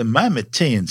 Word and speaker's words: Mimetean: The Mimetean surface, and yes, that's how you say it, is saturated with smaller impact craters, but no others are anywhere near the size of Herschel.